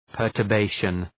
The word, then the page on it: perturbation: ,pɜ:rtər’beıʃən